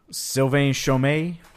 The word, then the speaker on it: Sylvain Chomet: Okay, so to continue on I'm going to butcher this name, Sylvain Chomet trip.